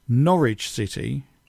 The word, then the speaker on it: Norwich City: Now, Norwich City, which is how we'd pronounce it. – Which is how we would pronounce it. Amelia, being American, would have pronounced it Norwich City.